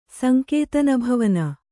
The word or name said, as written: ಸಂಕೇತನಭವನ